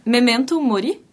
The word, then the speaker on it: Memento Mori: Fala um pouco sobre a concepção desse repertório, que a gente está ouvindo um pouco, e ele se chamou Memento Mori, uhum. Assim que pronuncia mesmo, em torno da ideia da morte.